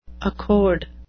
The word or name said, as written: accord